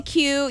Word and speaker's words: queue: The word Q is ironic. It's just a Q sitting there with a bunch of silent letters waiting in a queue. They're just waiting for their turn.